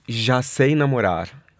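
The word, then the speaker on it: Já Sei Namorar: It's Yase Namorar. – Okay. – You were almost there. – The J is always a bit problematic in Portuguese.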